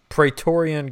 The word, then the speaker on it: Praetorian: With confidence. Praetorian Guard. – Yeah. – Yeah. – You got it. Good job.